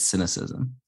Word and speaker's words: Sinocism: People often wonder how to say it because it's spelled S-I-N-O-C-I-S-M, but it's not cynicism, right, Bill? It's cynicism. – Yeah, I'm too cynical, so I figured I'd have something that rhymed with it.